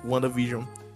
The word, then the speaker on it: WandaVision: Só que aí, tipo, ele junta tudo aí fica Wanda e WandaVision. Porque na pronúncia, quando tu fala rápido, sai WandaVision e não Wanda and Vision. Não tem um N, é tudo junto.